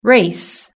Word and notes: race: British English

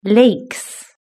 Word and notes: lakes: british-english

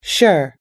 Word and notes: sure: us-english

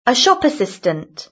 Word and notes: a shop assistant: British English

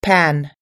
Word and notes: pan: American English

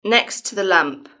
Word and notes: next to the lamp: British English

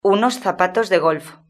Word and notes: unos zapatos de golf: European Spanish

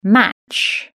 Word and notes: match: British English

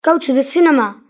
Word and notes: go to the cinema: British English

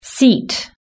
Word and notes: seat: british-english

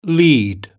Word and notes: lead: British English

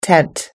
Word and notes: tent: American English

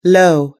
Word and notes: low: us-english

